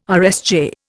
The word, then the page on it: RSJ: Indian